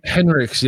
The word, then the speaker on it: Henrichs: Henrichs, yeah.